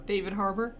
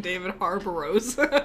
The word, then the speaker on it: Harbour: David Harbour. – Okay, well, I'll learn to spell that first. – Is this the guy who called him David Harbour-os? – Ghost.